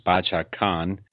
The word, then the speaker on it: Bacha Khan: I learned the name is Bacha Khan. – Yes, that's right.